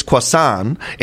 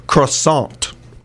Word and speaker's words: croissant: In French, it's croissant. In English, it's pronounced croissant. – With the T. – Yeah. Well, in a sant. Like the sant. You got to get in there. croissant.